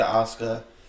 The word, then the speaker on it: Asuka: I'm thinking Bayley goes for something early that gives Asuka the win, keeps the belt for her.